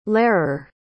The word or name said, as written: lehrer